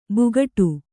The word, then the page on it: ಬುಗಟು: buguṭu